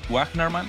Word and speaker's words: Wajnerman: How do you say the last name? – I pronounce it Wajnerman.